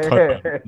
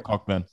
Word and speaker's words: Cockburn: And they're pronounced Coburn, not Cockburn.